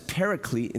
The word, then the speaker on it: paraclete: So parakletos, which we pronounce as paraclete in the English, is one who you call out to come alongside you, to come to your side, especially in times of need, right?